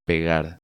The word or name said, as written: Pegar